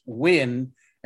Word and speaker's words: Nguyen: He's a freshman, Ethan, and his last name is spelled N-G-U-Y-E-N, but it's pronounced Wynn.